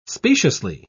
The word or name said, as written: speciously